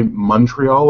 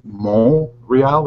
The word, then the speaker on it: Montreal: English people say Montreal like it has a U, and French say Montreal like it has a long O. Only Americans say Montreal.